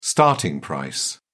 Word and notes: starting price: British English

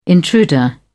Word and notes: intruder: British English

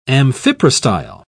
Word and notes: amphiprostyle: British English

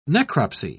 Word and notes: necropsy: British English

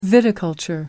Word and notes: viticulture: American English

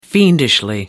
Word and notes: fiendishly: American English

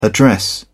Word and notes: address: British English

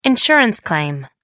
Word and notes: insurance claim: American English

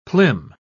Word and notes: plim: British English

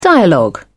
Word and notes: dialogue: British English

